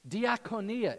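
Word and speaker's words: Diakonia (διακονία): Diakonia, it sounds like the word deacon, means servant.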